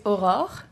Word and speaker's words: Aurore: The French way is Aurore.